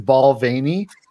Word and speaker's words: Balvenie: I hate that I used to pronounce the name of that whiskey wrong for like the first 10 years. I loved that whiskey. And then when I learned it was Balvenie, I'm like, that is one way to pronounce a name.